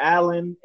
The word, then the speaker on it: Allen: Alan, A-L-L-E-N, Levi, L-E-V-I, Simmons.